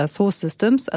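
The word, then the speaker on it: Dassault Systèmes: Aber viele machen das eben mit der Software von Dassault Systems. Ich hoffe, ich habe es einigermaßen richtig ausgesprochen. Oder Dassault Systems, also ohne das T hinten dran, französischer Konzern.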